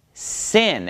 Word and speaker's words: Sin: Then it becomes pronounced sin, and it's a s sound, just like samech, all right?